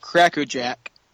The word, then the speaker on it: Cracker Jack: And a bag or two of Cracker Jack. Not Jack's. – Yeah. – There's no S, people. Saying it right.